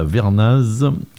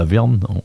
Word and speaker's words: la Vernaz: On parle parce que vous leur donnez un bon coup de main, je pense, de la montée historique de la Vernaz, ça se prononce comme ça, ou la Verne, en bon savoyard d'ailleurs, qui va se passer le dimanche 19 mai.